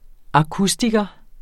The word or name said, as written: akustiker